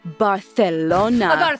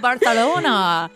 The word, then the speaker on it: Barcelona: Well, she's correcting me. She knew we'd read it wrong. Pronounced Barcelona, we have now learned. – Oh, Barcelona.